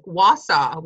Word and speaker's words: Wausau: And I want to just give you some kudos for saying Plautz right and Wausau, Wisconsin, because so many people do not pronounce Wisconsin cities properly.